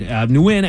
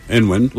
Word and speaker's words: Nguyen: Some Vietnamese people swear it's pronounced Nguyen. – Nguyen. – With the first N spoken out loud. Some say that you drop the first letter and simply pronounce it as Win. And others insisted that it should be Nguyen.